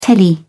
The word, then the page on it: telly: British English